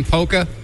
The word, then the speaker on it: polka: Hey, so do you pronounce the L in polka? – Oh, heck no.